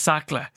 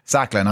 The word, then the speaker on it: Sacla: I keep pronouncing it wrong. That's my fault. It's Sacla. – Sacla, not Scala.